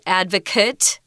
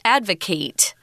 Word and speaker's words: advocate: Um, it's also a verb, but we pronounce it differently. So you need to be very aware of the pronunciation to know which form of the word it is. If it's a noun, it's advocate. And if it's a verb, it's advocate. So they're completely different.